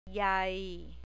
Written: yai

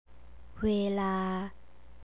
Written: weela

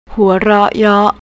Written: huaroryor

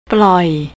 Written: ploi

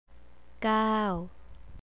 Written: kao

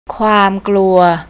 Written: kaumkluy

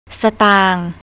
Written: satang

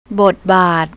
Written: botbat

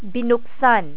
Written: binuksan

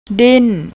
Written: dint

